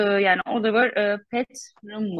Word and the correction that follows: Putnam: mu? Petnam mı? Broadway'de başarılı bir yönetmen. Putnam. Putnam.